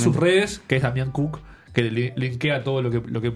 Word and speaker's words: Kuc: El nombre, la URL del canal es Damián Cook. Cook se escribe K-U-C, sí. – No es como cocinar. – Sí. – K-U-C, ok.